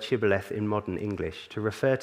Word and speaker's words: Shibboleth: If he replied, "No," they said, "All right, say Shibboleth." If he said Sibboleth because he could not pronounce the word correctly, they seized him and killed him at the fords of the Jordan.